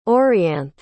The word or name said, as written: orianthe